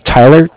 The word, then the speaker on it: Tyler: My name is Tyler, T-Y-L-E-R, and I'm calling with Cardiff. It's spelled C-A-R. D-I-F-F, as in Frank, Frank.